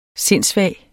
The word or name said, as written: sindssvag